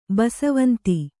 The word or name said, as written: ಬಿಸವಂದ